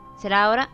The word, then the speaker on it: Zeraora: Zeraora, no sé cómo se pronuncia. – ¿Zeraora? – Zeraora, ajá.